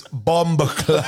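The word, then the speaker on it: Bumbaclot: Can I teach you how to say it properly? It's Bumba Clot.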